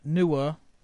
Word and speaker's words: Noor: Stanley Green for the Noor, I think that's how you pronounce it, N-O-O-R, Noor, Noor, Noor? We'll say it's Noor.